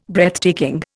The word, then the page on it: Breathtaking: Indian